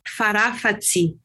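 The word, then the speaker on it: Farafatsy: You pronounce it Farafatsi.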